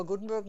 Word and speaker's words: Gutenberg: concludes our change log for Gutenberg 9.8.